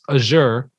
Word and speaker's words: Azure: Azure cloud platform. I believe I pronounced that correctly. – You did.